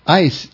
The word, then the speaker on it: eis: Ais is the, E-I-S is the way it's spelled.